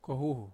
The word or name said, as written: kohuhu